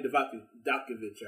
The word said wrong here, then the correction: Dijakovic: or however you say his name. – Dominic Dijakovic. – Dijakovic. – Yeah. – There you go.